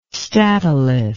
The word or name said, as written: statolith